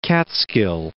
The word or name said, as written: Catskill Mountains